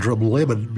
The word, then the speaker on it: Drumlemble: And it's, if you go looking on any map of Scotland, you probably won't find Drumlemmon, because that's the local pronunciation of the village of Drumlemble, which is on the Mull of Kintyre, somewhere down near Campbelltown.